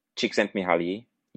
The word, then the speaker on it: Csikszentmihalyi: And then there's Csikszentmihalyi. Wait, did I say his name right? – Close enough. Csikszentmihalyi, yeah.